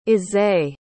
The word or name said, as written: isai